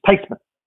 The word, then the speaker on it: Theismann: She says, "The correct pronunciation is Theismann. And that's actually closer than what we have now."